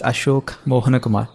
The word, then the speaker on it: Ashok Mohan Kumar: So my name is Ashok Mohan Kumar. That's how it is pronounced. And there are syllables in there that my American friends can't pronounce.